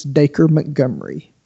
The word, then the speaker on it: Dacre Montgomery: But uh, Dacre Montgomery as as Billy, and that is how you pronounce that, Jamie Dacre. It's like Dacre okay, the D. Yeah, it's, uh, that was from his own mouth. It's Dacre Montgomery.